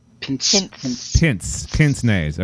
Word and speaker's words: pince-nez: Pinch-nays. – Let's bring back pinch-nays. – Am I not saying it right? – I'm hearing pinch. – Pinch. – Pincer. – Pince-nays. Okay, pince-nays. – Pince, yeah.